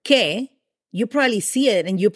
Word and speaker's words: que: So what in Spanish is said que, and it is spelled Q-U-E.